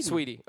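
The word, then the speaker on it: Saweetie: Sweetie. – Okay. Because it's S-A-W, right? – Yeah. – Yeah, it's so Sweetie. – Sweetie? – It's like Wheaties, but, you know. – Sweetie.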